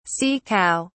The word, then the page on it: sea cow: British English